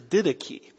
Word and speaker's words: Didache: By the way, don't pronounce that the Didache. I was talking to one of my friends on the phone and he kept calling it the Didache. And no, that's not pronounced the Didache. It's the Didache.